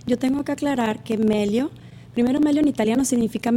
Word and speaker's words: Meglio: Se escribe Meglio, pero el ajeno suena. – Para efectos... Meglio Ordóñez, el pelotero. – Bueno, creo que ese es Maglio, ¿no? – Mira, Carlos es solidario. Carlos se ríe en mi tontería. – Ajá. Sí, entonces la G no suena para efectos de pronunciarla, pero sí es importante que la escriban para poder encontrar las páginas y toda la información.